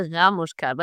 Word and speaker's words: Ramushkat: Well, I would stress the A, so it's a Ramushkat, but it's a weird last name, okay.